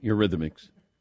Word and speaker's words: Eurythmics: Am I saying this right? The Eurythmics? – Yeah, Eurythmics.